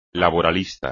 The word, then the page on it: laboralista: Spanish